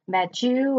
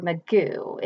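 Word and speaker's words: Mageau: Now, the badass number 5, I can't pronounce his last name. It's Mike Magu or Magu. It's M-A-G-E-A-U.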